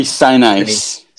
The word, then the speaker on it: Sinise: Gary G- Gary Sinise.